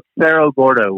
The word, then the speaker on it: Cerro Gordo: And how do you pronounce the name of this town again? – The town's called Cerro Gordo, which in Spanish means Fat Hill, because once upon a time it was the largest producer of silver for the state of California.